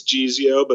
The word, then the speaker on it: Geezeo: Geezio, for those that don't know, or did I say it right, Geezio or Geezio? – It's Geezio, but I've heard it called a lot of things.